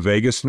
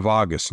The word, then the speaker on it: vagus: It's the activation of what we call, there's two ways this is pronounced in the medical community. It's either pronounced the vagus nerve, V-A-G-U-S, or the vagus nerve. I've heard it pronounced two ways by medical professionals.